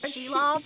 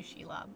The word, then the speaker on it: Shelob: Shelob? – Shelob. – All right, you can say Shelob.